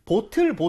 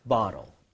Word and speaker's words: bottle: 그래서 예, 오에다 강세를 좀 찍어서 오에 강세가면 입모양 대부분 벌려진다고 했었죠. 자, 두 번 발음을 해보도록 할게요. bottle도 좋은데 이렇게 해볼게요. bottle, bottle, bottle.